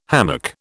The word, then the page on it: hammock: British English